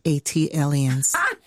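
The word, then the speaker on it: Atlantans: That is pronounced Atelians. – Thank you. – Like Atlanta alien.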